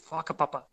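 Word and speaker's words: whakapapa: So the way that you in traditional Te Reo Māori, which is the language of the natives of New Zealand, a W and an H is pronounced as a so an F. So fuck a tāne, fonga nui, fuck a papa, that kind of stuff like.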